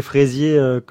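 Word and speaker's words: Frézier: Alors son nom, ça ne s'écrit pas exactement comme les Fraisiers que l'on connaît, hein, c'est Fraisier, F-R-E-Z-I-E-R, mais il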